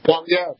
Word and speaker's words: Poilievre: Poilievre? – Poilievre.